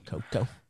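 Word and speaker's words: Koekkoek: I can't even say his name. Koki? – Slater Koku. – Koku. Slater Koku. 1 year Coco. – Deal. – Just say Koko. – Koko. – There you go.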